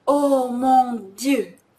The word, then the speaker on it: Dieu: こちら、 Dieu という一つの発音ではなくて、 D、 D を言ってから、う、このあの口でうを言う、ちょっと半端な音ですね。う、じゅ、じゅという二つの母音をきちんと発音するところがちょっとしたポイントになります。じゅ、じゅ。おーもんじゅですね。